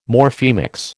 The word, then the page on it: Morphemics: American English